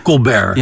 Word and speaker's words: Colbert: Ja, zie jij Stephen Colbert, zeg ik het goed zo? – Colbert.